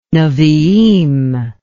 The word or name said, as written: nebiim